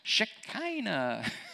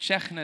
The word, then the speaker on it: Shekhinah: But from Mishkan, from the tabernacle, is Shekhinah. And it's another form of that. And this is the presence. You've probably heard it as Shekinah. But Shekinah is the proper pronunciation of it.